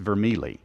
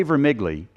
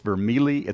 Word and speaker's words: Vermigli: All right, so theologian today, and I'm going to mispronounce his name, and I'm sorry, because I want to say the G, but he's Italian. It's Vermigli. And if I say Vermigli, it's wrong. It's Vermigli, it's Vermigli, it's Vermigli.